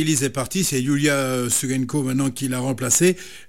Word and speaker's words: Yiula: Alors, je prononce Yulia parce que ça s'écrit Y-I-U-L-A, qui la remplace et qui la remplace plutôt bien.